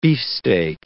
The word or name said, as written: beefsteak